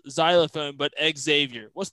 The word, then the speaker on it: Xavier: And he's like, it's pronounced – Xavier, like xylophone.